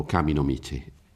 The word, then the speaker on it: Kami no Michi: Ecco, Shin, volta lei ce l'aveva detto, Shin da Shinto è la pronuncia alla cinese, quindi Shinto è la via dei Kami, però Kami no Michi, pronunciato alla giapponese, ma ecco.